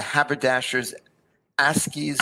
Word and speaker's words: Haberdashers' Aske's: So for those of you unfamiliar, Rabbi Chaim Miller was educated at the Haberdashers Aske School, did I pronounce that right, in London? – Yes.